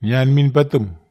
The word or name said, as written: nian min patem